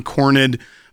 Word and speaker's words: Cornyn: Vance said in that Sean Hannity interview and what John Cornyn post on X. I said it right this time, Logan. You happy about that? – It's very good.